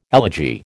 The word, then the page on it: elegy: American English